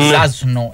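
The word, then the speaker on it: Zasno: Ζάσνο, έτσι προφέρεται ο συγκεκριμένος ποδοσφαιριστής.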